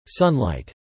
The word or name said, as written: sunlight